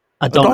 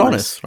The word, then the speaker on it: Adonis: Adonis. – Adonis right? I like thought the... I thought the S was silent. No.